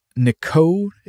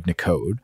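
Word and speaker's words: Nicaud: boogie spelled B-O-O-G-I-E in this case. So Eden says, the late great Steve Nicoud is how I think you would pronounce it. It's N-I-C-A-U-D. I think that would be Steve Nicoud.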